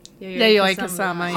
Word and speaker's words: Yayoi Kusama: Yayoi Kusama.